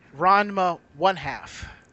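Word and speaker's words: Ranma ½: Remember, it's Ranma one half.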